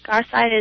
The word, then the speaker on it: Garside: And Melissa is spelled M-I-L-I-S-S-A. And Garside is G-A-R-S-I-D-E.